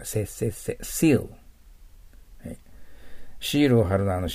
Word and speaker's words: seal: Please put your seal って言っちゃダメです。シールです。切手のシール。日本語で言うと。でも、カタカナのシじゃないんですね。